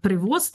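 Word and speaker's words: Prevost: И меня удивительно совершенно, что он из США, что, да, родившийся в Чикаго, и в, получается, что в английской транскрипции они действительно кардинал Роберт Превост его произносит, в русскоязычной Прево, да. Прево, или Прево, да. Может быть, даже.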